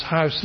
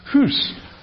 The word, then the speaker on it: house: So in Chaucer's time, the word house, which we pronounce house these days, the third one, would have been pronounced hoos. Now, interestingly, in Scotland, that's exactly how they still pronounce it.